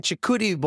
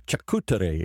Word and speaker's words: charcuterie: It's like a chicuri board, but Mexican style. – A charcuterie. Yeah. – How do you say that? Chicuri board, charcuterie.